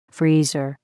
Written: freezer

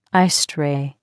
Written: ice tray

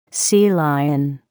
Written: sea lion

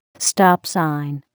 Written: stop sign

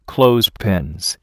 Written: clothespins

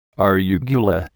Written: arugula

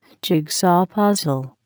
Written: jigsaw puzzle

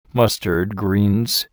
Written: mustard greens

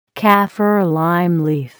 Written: kaffir lime leaf

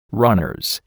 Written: runners

